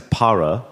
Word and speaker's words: Parra: This is Parra. – Parra. – That's how we pronounce it. Piet Parra.